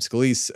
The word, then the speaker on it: Scalise: that's how you say his name, Scalise, was no longer viable because Trump yelled about him having blood cancer.